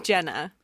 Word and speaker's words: Jenna: But sometimes people mistake Jenna for Jenny. And in that point, it's like, okay, I got to tell you, my name's Jenna because I won't answer to you otherwise.